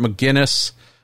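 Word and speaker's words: Megennis: Got to see Robert Maginus. I think as we figured out how to pronounce his name last time he was on the show, Robert McGinnis, saw him first.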